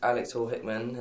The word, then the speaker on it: Alex Hall Hickman: My name is Alex Hall Hickman, and it is spelt AlexHallHickman.